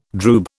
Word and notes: droob: British English

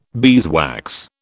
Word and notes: beeswax: British English

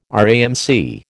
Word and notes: RAMC: American English

